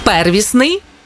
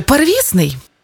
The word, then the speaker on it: первісний: первісний чи первісний? Як правильно? У цьому слові подвійний наголос, тому правильно первісний і первісний.